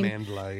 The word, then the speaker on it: Amandla: But no wonder then you're going to breed a whole nother generation of Amandla. Is that how you pronounce her name? – Amandla, yeah.